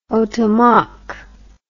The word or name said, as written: otomac